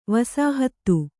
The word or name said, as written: ವಸಾಹತ